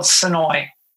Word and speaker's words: Senoia: The town is called Senoia. S-E-N-O-I-A. – Okay. – A tourist will pronounce it Senoia. And uh-huh. That's how we know you're a tourist.